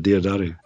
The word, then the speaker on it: Diodati: Am I saying that correctly? Is it? – As far as I know, that's the way I've always said it, Diodati.